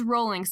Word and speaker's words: Rowling: Rowling or Rowling. She says it's Rowling, so I hope that we're on the same boat. That's how you pronounce it.